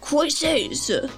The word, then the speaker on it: cuisses: It's like the French word for thigh. – Well, then you gotta say it with an accent. Cuisses.